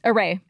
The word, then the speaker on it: Arrae: Array. – Array.